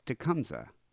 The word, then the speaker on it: Tecumseh: He was a native of, I'm going to say this wrong, so you'll probably have to tell me, Temushia? Temushia? – You're not even trying to say it phonetically. It's Tecumseh.